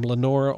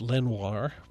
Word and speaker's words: Lenoir: He talks about him being his friend, but J.B.'s pronounced his name Lenore, although it was French spelling, and on this song, Mayall says Lenoir, which is not the way that J.B. pronounced his name.